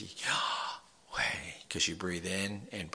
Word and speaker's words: Yahweh: Is Yahweh, and that it's YHWH, but it has no vowels, and that the proper way to pronounce it in Hebrew is Yah, you breathe in, and Way, you bring out. So it's Yahweh, and it's Yahweh.